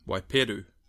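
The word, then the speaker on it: waipiro: So, please correct me, if I pronounce this wrong. If you were drinking Waipiru, what would you be drinking? – Waipiru. – So, Waipiru.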